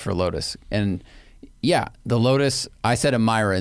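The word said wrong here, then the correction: Emira: in the video, and it's pronounced Amira. But the Avaya is Avaya. So yeah. It's like, that's a hard I. So I thought the Amira was also a hard I. It is a soft I.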